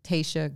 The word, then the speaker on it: Tasha: So my Instagram handle is freaking awesome Tayshia, and it's spelled like Tasha. And my Facebook is Tayshia Gray, T-A-S-H-A.